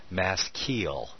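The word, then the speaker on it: Mashchil (מַשְׂכִּיל): To the chief musician, Mashchil. And this is another word that is Hebrew. Mas, to pronounce it, the phonetic pronunciation is Mashchil.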